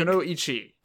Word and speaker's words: Kunoichi: Uh, but okay, so let's get right into episode forty-one, which is called Kanoichi Rumble. – Is that uh, how you uh, pronounce Kanoichi? Kunoichi, I think. Kunoichi.